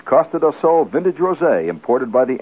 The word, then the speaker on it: Costa do Sol: And it's Costa do Sol. Costa do Sol. It's spelled C-O-S-T-A, Costa, Coast, D-O, of, Sol, S-O-L. Sun means the coast of sun, the sun coast.